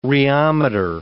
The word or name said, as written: rheometer